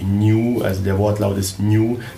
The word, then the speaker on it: Niiu: und über New. New. Sie, ja schreibt man wie? N-I-I-U. – Und das heißt eben so New, so jeder denkt so, das ist was Japanisches.